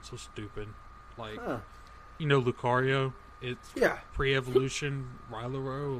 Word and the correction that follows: Riolu: Riolu, whatever.